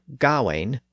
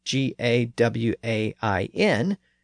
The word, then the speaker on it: Gawain: First, if we use the normal spelling G A W A I N, we encounter that letter combination AI in the second syllable. In Old English, that spelling typically represented the I sound so that would give us the pronunciation Gawain. But as I noted a few episodes back when I was talking about Chaucer, the sound represented by that spelling shifted in the late Middle English period and became more like a, at least in Chaucer's dialect.